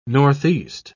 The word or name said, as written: northeast